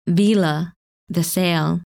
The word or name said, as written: Vela